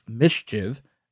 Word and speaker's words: MSCHF: Now, this idea was launched by the MSCHF, which is pronounced Mischief, Art Collective.